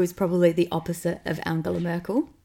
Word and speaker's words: Angela: I was going to say Angela Merkel, but I was going to try and say her name correctly, which is like Angela.